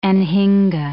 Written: anhinga

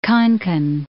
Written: Konkan

